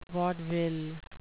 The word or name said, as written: vaudeville